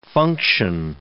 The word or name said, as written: function